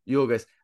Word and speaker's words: Yorgos: Yorgos.